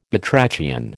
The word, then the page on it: batrachian: American English